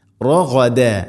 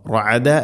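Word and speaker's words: റഗദ (رغدا): റഗദ, റഗദ, ഗൈന് തൊണ്ടയുടെ മുകൾ ഭാഗം, റഗ, റഅദ അല്ല റഗദ. റഗദൻ വദ്ഖുലു, തൻവീന് ശേഷം വ വന്നാൽ ഇദ്ഗാം ബിഗുന്ന, റഗദൻ വ മണിച്ചുകൊണ്ട് ഉച്ചരിക്കണം, തൻവീനിനെ വയിലേക്ക് ഇദ്ഗാം ചെയ്യണം.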